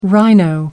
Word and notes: rhino: American English

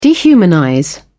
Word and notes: dehumanize: British English

